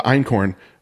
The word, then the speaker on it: einkorn: And so for you, so the einkorn, did I say that right? – Yeah.